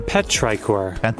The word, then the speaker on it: Petrichor: Petrichor.